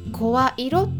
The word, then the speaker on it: 声色: 声の色と書いて声色って読みますけれども、